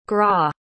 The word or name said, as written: gras